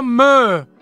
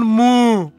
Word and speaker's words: Meaux: No, si pronuncia me con la O chiusa, non mu. Il paese Meaux, da dove arrivano questi qua che abbiamo appena passato.